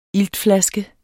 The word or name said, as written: iltflaske